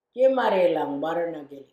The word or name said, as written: jimarrilang barrernha gili